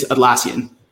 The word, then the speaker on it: Atlassian: I love that we're talking about this because so how do you say your company's name? Atlassian. – It's Atlassian.